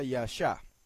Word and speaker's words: Yasha: Okay, en la segunda, debajo de la Yod va una rayita, debajo de la Shin otra rayita, y se pronuncia Yasha.